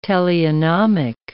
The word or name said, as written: teleonomic